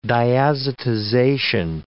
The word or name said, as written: diazotization